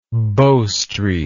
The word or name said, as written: Bow Street